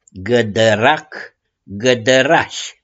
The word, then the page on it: GĂDĂRAC: GĂDĂRAȘI